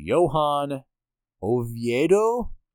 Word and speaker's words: Oviedo: which is going to be Johan Oviedo. I was actually pretty close to that. That's how it's pronounced.